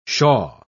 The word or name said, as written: shaw